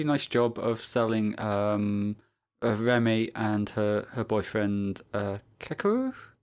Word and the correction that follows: Kakeru: Oh, I can't pronounce names. Sorry, Andy. You'll have to just... – Hold on. His name is Kakeru.